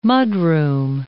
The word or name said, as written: mudroom